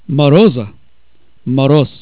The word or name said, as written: moroz